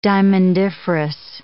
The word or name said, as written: diamondiferous